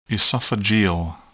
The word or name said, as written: esophageal